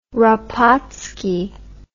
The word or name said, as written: rapacki